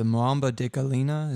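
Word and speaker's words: muamba de galinha: Was it the Mwamba de Galina? Is that how you say it? – Yes.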